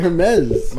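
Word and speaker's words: Hermès: That's how you pronounce it, Hermes.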